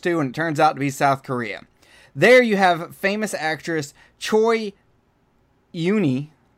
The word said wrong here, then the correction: Choi Yun-hee: Don, did I say that right? Choi Yuni? – There should be a space. Choi Yun-hee. – Okay. Choi Yun-hee, who is a famous actress from South Korea.